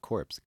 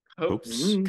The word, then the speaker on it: copse: It's not corpse. Cops? – Copes. – Copes? Speaky copes.